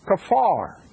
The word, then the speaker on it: kaphar: And the Hebrew word that is translated atonement was kafar. It's spelled K-A-P-H-A-R in the English, and the Hebrew pronunciation is more or less K-A-W-F-A-R, kafar, as I can understand translation.